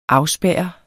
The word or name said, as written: afspærre